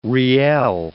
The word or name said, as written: riel